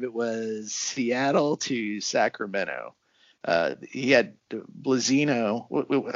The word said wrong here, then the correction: Blazina: I'm sorry, I got his name wrong. Is it Blazina? – Yeah, Blazina.